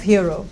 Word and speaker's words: Hero: Almost incidentally, the name of Hero, to which Claudio seeks to make his bride accountable, rhymes with zero.